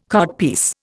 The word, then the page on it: codpiece: Indian